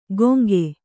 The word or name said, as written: ಗೊಂಕೆ